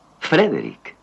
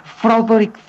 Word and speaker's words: Frederick: No, Frederick. – ¿Y por qué no es Froderick Frankenstein? – Porque no, es Frederick Frankenstein.